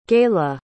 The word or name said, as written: Gaila